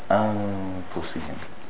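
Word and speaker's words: أنفسهم: Fi anfusihim, and you notice the fa, the way I pronounced it, anfusihim.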